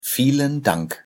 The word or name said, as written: vielen Dank